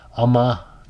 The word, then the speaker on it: Ummah: Ummah, that's pronouncing right, U-M-M-A, and almost 300 million Americans.